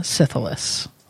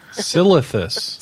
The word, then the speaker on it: Silithus: So I'm laughing because I had a friend who could not, for the life of him, pronounce Syphilis. – Silithus.